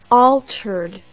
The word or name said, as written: altered